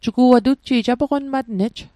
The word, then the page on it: tutji'j: Mi'gmaq